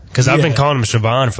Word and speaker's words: Shavon: So, Philip, did you know that Shavon Revel was actually pronounced Shavon? – I knew I had been told that the H wasn't there, so I did know it was Suh. I didn't know there was so much emphasis on the O at the end. I just thought it was Shavon, not Shavon.